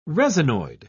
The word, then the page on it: resinoid: British English